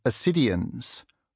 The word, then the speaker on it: ascidians: Amid the sponges are what look like ferns and flowers, and also ascidians with a silent C, pale pink spout-like structures with enamel patterns inside.